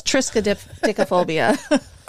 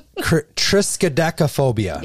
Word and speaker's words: Triskaidekaphobia: It's Triskaidekaphobia. – Okay. Triskaidekaphobia.